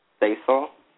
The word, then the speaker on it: Stacil: Basil. – How do you spell it? – S-T-A-C-I-L. It's like Stacy, but no Y.